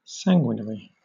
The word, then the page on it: sanguinary: British English